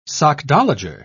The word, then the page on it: sockdolager: British English